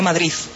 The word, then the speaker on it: Madrid: Pero fíjese, además, usted que ha vivido en España, en Madrid se dice Madrid, no Madrid. – Sí, Madrid. Madrid. – Madrid. – Eso es la última, no se pronuncia.